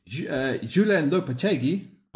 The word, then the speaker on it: Julen Lopetegui: Julen Lopetegui. – Oh, that was good. – That was okay. That was all right. But Julen Lopetegui was announced just moments before,